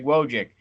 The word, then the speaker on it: Wojcik: And Greg Wojcik finally came into Mayhem and told me I'm saying his name correctly on there. So, Greg Wojcik, he took Rainbow Trout with a 3.02 pounder.